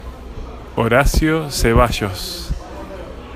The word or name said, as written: Horacio Zeballos